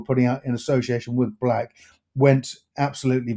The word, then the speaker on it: BLVCK: Also worth noting as well, earlier this year, the company signed signed an agreement to advise global lifestyle fashion brand Black. Now, Black is spelled BLVCK, so those that know it will know.